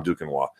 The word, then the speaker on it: Duquesnoy: You got to see this kid, Tom Duquesnoy.